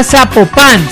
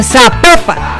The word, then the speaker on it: Zapopan: Oiga, nos escriben los oyentes y que no, no se pronuncia Zapopan, sino que se pronuncia Zapopan.